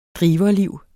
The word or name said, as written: driverliv